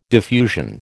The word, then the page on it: diffusion: American English